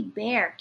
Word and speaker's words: Bexar: Ooh, it's actually Bexar County. – Oh? – The X is silent.